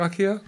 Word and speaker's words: IKEA: How do you pronounce it? Do you pronounce it IKEA? – Yeah.